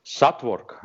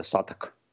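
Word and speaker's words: Southwark: И, например, вот район, все русские произносят его Сатворк, а на самом деле это Саток.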